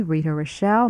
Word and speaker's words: Rita Rochelle: So, if you'd like to get a copy of the current VOA program schedule, simply write to me, Rita Rochelle. My name is spelled R-I-T-A-R-O-C-H-E-L-L-E.